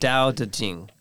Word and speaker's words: Tao Te Ching: You see, so a lot of people mistakenly say like Tao, but it's actually pronounced with a D. It's Tao.